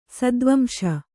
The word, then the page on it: ಸತ್ವಾಂಶ: satvāmśa